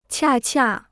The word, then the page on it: 恰恰: qià qià